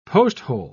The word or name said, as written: posthole